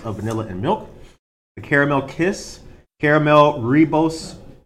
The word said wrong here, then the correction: Rooibos: Is that ribos? – Rooibos. Rooibos.